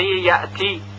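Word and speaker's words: يأتي: مثلا این طور تلفظ میکنیم کلمه یأتی، یأتی، یأتی. و اگر این طوری که الان گفته میشه تلفظ بشه یأتی، یأتی، غلط هست، چون همزه که ساکن هست قلقله پیدا کرده و ما میدونیم که همزه از حروف قلقله حساب نمیشه.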